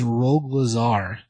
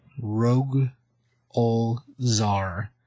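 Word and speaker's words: Rogol Zaar: But just so people know, this is Rogue Lazar. Rogue Ol Zar. Let me spell it for you. It's R-O-G-O-L, then Z-A-A-R. That's two A's in there. Rogue Lazar.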